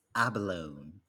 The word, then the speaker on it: abalone: How do you pronounce this? – It's abalone. – Well done, because I've heard a lot of Singaporeans go abalone. I'm like, it's not abalone, just like how it's not lavender.